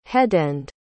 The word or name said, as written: headend